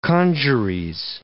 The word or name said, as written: congeries